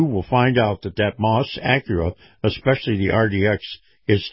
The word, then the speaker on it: Maus: Now, Moss is spelled M-A-U-S,